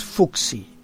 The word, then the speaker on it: Fuxi: El dios Fuxi, se escribe F-U-X-I, también conocido por otras denominaciones como Baoxi, Foji, Fuxi, Fuge, Fu-H-S-I, Fuxi-Shi, Paoxi o Paoxi.